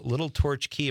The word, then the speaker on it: Little Torch Key: That's Steve from Little Torch Key. Am I saying that right? – Does that you sound familiar? – I'm not sure. Yeah, you are. You're saying it right.